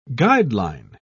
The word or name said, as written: guideline